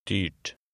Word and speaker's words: dyrt: Now try number 8, where the final T is pronounced. – Dyrt.